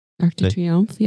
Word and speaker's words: Arc de Triomphe: But I remember when we were in Paris and I painted the Arch de Triomphe. – Arc de Triomphe, yeah. – Yeah, I said that right? – Yeah.